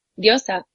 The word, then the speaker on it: diosa: But top of my list was to ask you what diosa is, and am I even pronouncing it right? – Yeah, diosa.